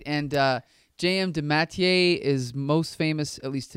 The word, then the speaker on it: DeMatteis: How did you pronounce it? – J.M. DeMatteis? – Yeah, I think you're right. No, I was going to correct you, but you're right.